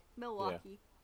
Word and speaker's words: Milwaukee: Milwaukee. I said that right, didn't I? Yeah. – Yeah, you did. – Hey. – I feel like it's such like a Midwest town, like Milwaukee.